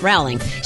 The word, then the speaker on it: Rowling: Rowling. Did I say that right? – Rowling.